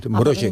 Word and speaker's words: Mrożek: Mrożek se escribe M-R-O-Z-E-K. Esa Z se lleva un puntito y en polaco y es como J. Mrożek.